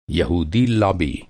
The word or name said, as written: यहूदी